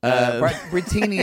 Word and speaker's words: Brittini: Brittini, it's like martini.